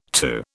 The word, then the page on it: TU: British English